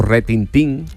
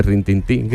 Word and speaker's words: retintín: Bueno, o retintín, que no se dice rintintín, que se dice rintintín, que es lo que venía todo por el perro.